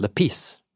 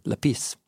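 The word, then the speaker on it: lepis (λεπίς): So the word is lepis, emphasis on the second syllable, lepis.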